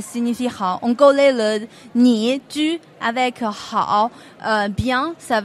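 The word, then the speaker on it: Ni (你): « Ni ».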